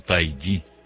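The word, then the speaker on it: Taigi: Es muy hermosa la vida de los santos y mala de Ana María Taiji, o Taiji, como se lleva el acento en la I.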